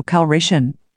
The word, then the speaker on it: Calrissian: He used to do the Colt 45 commercials when he wasn't playing Lando Calrissio. Just cool dude. – Calrissio is Italian. It's pronounced Lando Calrissian.